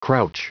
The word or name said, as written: crouch